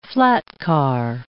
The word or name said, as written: flatcar